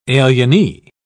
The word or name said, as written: alienee